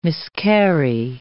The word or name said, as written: miscarry